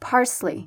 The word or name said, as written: parsley